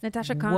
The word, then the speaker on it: Natasha Khan: Better because we have Natasha Khan with us today. Did I say your name right? – Yes. – Natasha Khan.